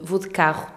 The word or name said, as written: vou de carro